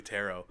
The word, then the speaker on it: tarot: Yeah, they need a certain accent to let you know this letter's silent, but they don't. – No, just guess. – But it's definitely tarot.